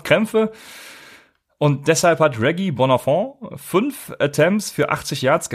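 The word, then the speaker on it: Bonafon: Ich habe noch einen kleinen Pick und das ist äh, Reggie äh, Bonafont. Ist das, spricht man das Französisch? – Ja, das hat sich gut angehört. Bonafont.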